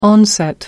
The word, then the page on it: onset: American English